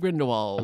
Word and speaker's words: Grindelwald: Crimes of Grindelwald. – Am I saying it right? – Yeah.